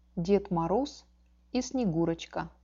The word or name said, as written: ded moroz i snegurochka